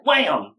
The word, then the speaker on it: Wham!: I've been mispronouncing Wham because it has an exclamation point. – Wham! Yeah. Not like Jeb, but...